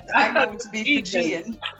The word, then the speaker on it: Fijian: Not Fugazi, it's Fijian. – Fijian. That's how you pronounce it. If it's from Fiji, it's Fijian. – I thought it was Fijian.